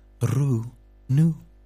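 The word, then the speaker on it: Rou Nouz: the fantastic Rounou, R-O-U, space, N-O-U-Z. A bit like Voulez-Vous, with a silent Z. Rounou.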